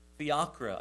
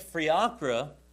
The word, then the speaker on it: Fiacre: and it was of Saint Fiacre, I think is how you say it, F-I-A-C-R-E, Saint Fiacre.